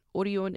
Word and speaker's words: Orion: Very similar to Lotte Entertainment and CJ Entertainment, Showbox comes from a food manufacturing company called Orion, or you might pronounce it as Orion, but Orion is how it's pronounced in Korean.